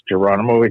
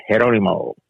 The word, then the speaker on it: Geronimo: Actually, it's not pronounced Geronimo. It's a Spaniard name. You actually pronounce it Geronimo.